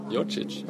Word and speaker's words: Djordjic: Ja, det är serbiskt. Hur enkelt som helst. Som i England brukar säga, det bara uttalas som George med en itch på slutet. Ja. Enkelt. – Djordjic.